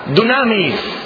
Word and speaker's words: dunamis: La palabra griega se pronuncia dunamis.